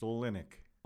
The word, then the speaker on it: Olenek: Close. You got the accent on the wrong syllable. It's Olenek.